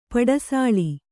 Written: ಪಡಿಸೂಳು

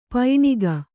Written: ಪೈಣಿಗ

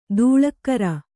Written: ಧಾಳಿಕಾರ

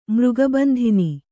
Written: ಮೃಗಬಂಧಿನಿ

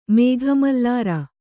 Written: ಮೇಘಮಲ್ಲಾರ

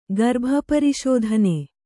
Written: ಗರ್ಭಪರಿಶೋಧನೆ

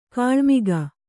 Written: ಕಿಳ್ಮೊಗ

